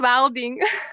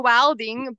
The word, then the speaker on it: Welding: Welding or Welding? – Just Welding. – Welding. You comboed it. – Yeah, it's Risha Welding, but I can't speak English, but my surname is English, and I can't weld, but my surname is Welding.